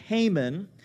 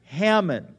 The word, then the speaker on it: Haman: And then there is this wicked officer named Haman, and it is Haman, not Hammon.